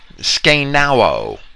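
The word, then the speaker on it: skenao (σκηνόω): The verb tabernacled is from the Greek word skenao. That's a difficult word to pronounce. It's an accented short O followed by an omega, which is a long O.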